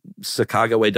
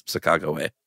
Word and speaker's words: Sacagawea: That's like the American bastardization. It's Sacagawea. – Oh, well, maybe we should keep this in. – Okay. – Because I've never heard anybody say that. I really thought you just mispronounced it. Other people say it like that? – Yeah, I think it's one of those things where like the native pronunciation is Sacagawea and Americans were like, Sacagawea.